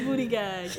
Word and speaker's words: Buttigieg: Is that how you say his name? – Booty gag? – I've been saying booty gag. – It is booty gag.